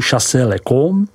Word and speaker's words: Chassey-le-Camp: Naší první zastávkou musí být vinařská obec Bouzeron, vyslovuj Buzoron, která je společně s obcí Chassey-le-Camp, vyslovuj Chassey-le-Com, jedinou oficiální apelací pro přípravu vín z bílé odrůdy Aligoté.